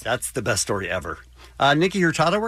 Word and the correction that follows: Nico Hurtado: we're going to talk about today. Did I say that right? Nico Hurtado. Thank you.